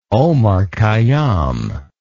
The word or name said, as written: omar khayyam